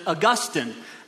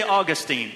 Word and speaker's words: Augustine: Now, if you're highly educated and a little pretentious, you might pronounce it Augustine, but we don't say Saint Augustine is the city where I live, so I say Augustine. Anyhow, potato, potato.